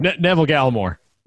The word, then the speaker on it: Neville Gallimore: Because even if you go a step further, the guys that they've drafted on defense, Galvin Nellamore, I don't know if I – did I say his name right? – Neville Gallimore. – No, sorry, Neville Gallimore.